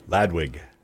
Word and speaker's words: Ladwig: Louding. – Ladwig. – Oh, wig. – No one's ever going to forget it. – W-H-I-G, like the party. – No, L-A-D-W-I-G. – Right, but wig is how it's pronounced.